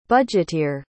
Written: budgeteer

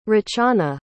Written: rachana

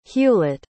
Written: hewlett

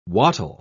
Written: wattle

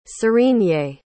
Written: zurine

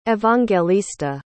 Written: evangelista

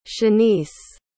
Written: shaniece

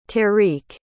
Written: tarique

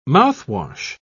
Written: mouthwash

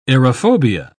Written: aerophobia

